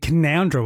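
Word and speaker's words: Canowindra: Keep the big peg at Canawindra, we're being told it's pronounced that way.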